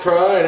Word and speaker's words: Crone: G'day everyone, welcome to the show, and today we've got on the show Mark Crone. If I pronounce the last name like Oral. – Yeah, it's good. – I don't know whether like Corona. – Yeah, silent E.